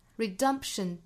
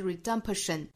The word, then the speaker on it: redemption: Redemption 中间这个 P 的发音不要后面拖音，不是 redemption, 是 redemption. 其实是上一下嘴唇眯一下之后就过度发后面的声了。